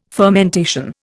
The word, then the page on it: fermentation: Indian